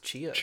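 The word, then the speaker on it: Tchia: T'Chaya? – I think it's just Chia. – Chia. Terra Nil and Vinba. – Chia won.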